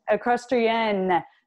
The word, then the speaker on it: Equestrienne: And it's called Equestrienne. Am I saying that correctly? – Equestrienne. – Yes. So you my poor French. – Yeah. Oh, yeah. Thank you. Well, so you fixed my pronunciation there.